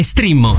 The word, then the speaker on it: Streammo: Questo è Strimmo. Un nuovo sistema facile e veloce per ascoltare un mondo di contenuti senza limiti o costi. Entra adesso nel mondo di Strimmo. Vai su strimmo.it. Si scrive Streammo o scarica l'app.